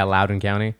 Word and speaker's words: Loudoun: On today's show, we're going to talk about, how do you pronounce that, Loudoun? – Yeah, Loudoun County.